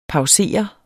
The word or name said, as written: pausere